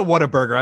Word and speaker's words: Whataburger: It's not an R in Whataburger. I think you. Whataburger. Oh, it's what's a burger? – Pronounce it right. – Yeah. – Oh, what a burger. What a burger. – It's like, what a burger. Like, it's like if you what ate a burger and you're like, what a burger.